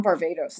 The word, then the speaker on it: Varvatos: I was at John Varvatos. Is that how you say his name? The clothing designer? Yep.